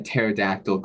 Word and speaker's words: pterodactyl: Why can't you hear a pterodactyl go to the bathroom? – Because the P is silent.